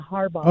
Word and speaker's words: Harbaugh: My last name is Harbaugh. – Harbaugh? – Yeah, Harbaugh. – Oh, like the football coach.